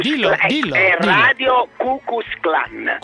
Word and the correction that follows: Ku Klux Klan: Ku Klux Klan, non Ku Klux.